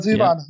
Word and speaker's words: Zevon: Warren Zevon.